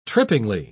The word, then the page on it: trippingly: British English